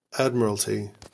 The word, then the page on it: admiralty: American English